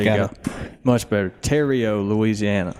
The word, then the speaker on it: Theriot: We are in Terrio, Louisiana. I would have not said that right. Terrio? Yeah, I thought it was Theriot.